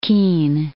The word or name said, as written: Kean